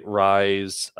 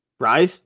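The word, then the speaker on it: Raes: Ryze? Okay. – Yeah. R-A-E-S. I'm pretty sure I'm saying that right.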